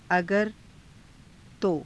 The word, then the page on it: agara..to: Hindi